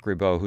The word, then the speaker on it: Ribot: B-A-T-E-A-U. – Yeah, I believe so. So Mark Ribot, whose last name is spelled, by the way, R-I-B-O-T, French style, and he's going to be at the Sudden Sound series at the Crandon Art Museum tomorrow night.